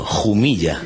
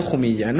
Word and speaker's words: Jumilla: Siempre en mi pueblo, Jumilla. Sí, pero es que te lo puedo asegurar, que yo en mi pueblo es importante. Yo siempre que voy, compro vinos, busco vinos, vino de Jumilla, no Jumilla.